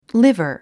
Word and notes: liver: lívər; リバァ

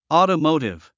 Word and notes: automotive: ɔ̀ːtəmóutiv; オートモウティブ

